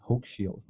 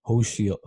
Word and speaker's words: Hochschild: Well, you know, as we were just saying, your last name is pronounced Hochschild, right? – Correct. – And until I started listening to your some of your interviews, I always thought it was pronounced Hochschild, Hochschild, Hochschild.